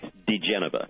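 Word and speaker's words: diGenova: Did I say that name correctly, Joseph? I got an Italian family, and yet I cannot pronounce the name. – It's DeGeneva. – DeGeneva. – Yep. – Joseph DeGeneva.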